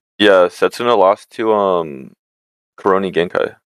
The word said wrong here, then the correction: Kuroki Gensai: Kuroki Genkai. Sorry, I mispronounced it. Kuroki Gensai.